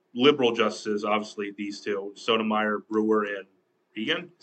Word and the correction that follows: Kagan: Is that how you say your name? I'm trying to figure out how you say your name. I keep forgetting how you say. Justice Roberts, Kagan. That's how you say your name. Kagan.